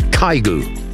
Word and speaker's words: Kygo: But his actual pronunciation, which I have now written out phonetically, is Kygo.